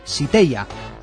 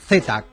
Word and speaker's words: Zeta: Lo tomó de su abuela paterna y la correcta pronunciación en su idioma es Siteia. Aunque para nosotros siempre será Zeta, Catherine Zeta-Jones, una de las estrellas más especiales del cine de hoy.